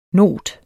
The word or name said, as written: not